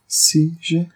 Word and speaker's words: Seiji: Jun Murakami as Saijei Hayami. Saijei? Is that how you say it? You know what I'm talking about? – Saijei? – S-E-I-J-I. – CJ? – Yeah, he's the reporter guy.